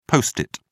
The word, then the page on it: post-it: British English